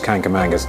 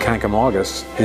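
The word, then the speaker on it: Kancamagus: I think the most common pronunciation is Kankamangus. Kankamangus I've heard probably more often than Kankamaugus, but Kankamaugus is the correct pronunciation.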